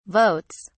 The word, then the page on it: votes: British English